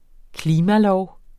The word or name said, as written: klimalov